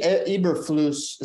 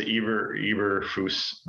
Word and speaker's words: Eberflus: Somebody said they think the L is silent, which I think is strange. Have you ever heard of the L being silent? – Eberflus?